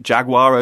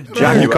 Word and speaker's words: Jaguar: Sorry, I pronounced Jaguar over here. Jaguar.